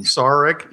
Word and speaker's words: Sarek: But I understand what I'm saying with the Spock's, with is it Sarek? I'm trying to get his name wrong. – Sarek, yeah.